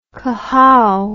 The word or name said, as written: cohow